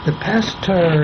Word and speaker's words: Pasteur: Now, the Pasteur Institute, which is, by the way, the way you pronounce it, is pronounced just exactly as stir your coffee, because it's French.